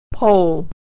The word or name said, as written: pole